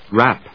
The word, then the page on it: wrap: rˈæp